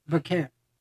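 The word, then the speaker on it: Vaquer: Yeah, but Velker, I don't know what I'm saying her name right. – Velker. Yeah. That's fine.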